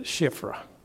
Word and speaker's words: Shiphrah: Says the king, then the king of Egypt spoke to the Hebrew midwives, of whom the name of one was Shiphrah. That's how you say it, Shiphrah.